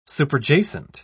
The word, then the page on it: superjacent: British English